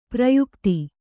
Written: ಪ್ರಯುಕ್ತ

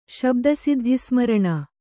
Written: ಶಬ್ದಸಿದ್ಧಿಸ್ಮರಣ

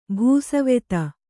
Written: ಭೂಸವೆತ

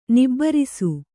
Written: ನಿಬಿರೀಸ

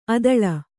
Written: ಅದಳ

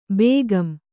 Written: ಬೇಗಂ

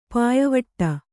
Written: ಪಾಯಿವಟ್ಟ

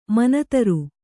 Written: ಮನತರು

